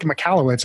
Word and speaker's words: Michalowicz: Mike Michalowicz. I hope I pronounced that name right. – You did.